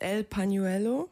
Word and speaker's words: El Pañuelo: Und euer erster Song ist El Pañuelo.